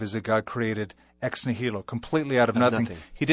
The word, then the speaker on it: ex nihilo: And I don't believe it's, I'm pronouncing it right, ex nihilo? – Ex nihilo. – Ex nihilo. That's correct.